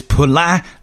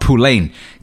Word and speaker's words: Poullain: It's Poulin, not Poulain. Come on, you ignorant motherfuckers. Say it right.